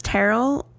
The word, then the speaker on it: Terrell: Next up was Terrell and Cheryl. That's how I remember how to say it. – T.O. Terrell. You get to say T.O. – I know, but Terrell and Cheryl rhyme, that works.